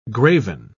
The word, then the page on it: graven: British English